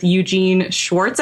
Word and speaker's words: Eugene Schwartz: So right now, my favorite business book is Breakthrough Advertising with Eugene Schwartz, I believe. That's how you pronounce it. – That is correct.